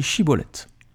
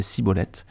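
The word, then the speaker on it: Schibboleth: Il lui disait alors Alors dis Chibolette. Et il disait Cibolette, car il ne pouvait pas bien prononcer.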